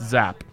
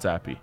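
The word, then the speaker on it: Zappe: He should tell everyone that it's pronounced Zap, not Zappy.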